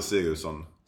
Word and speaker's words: Sigurdsson: Did I say that right? – Axel Sigurdsson. – Sigurdsson. – Yeah.